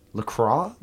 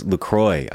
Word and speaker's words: LaCroix: Coffee and LaCroix and LaCroix? – So it's LaCroix. I went to their website. – What? – Uh-huh. And at the bottom it says... LaCroix, it rhymes with enjoy.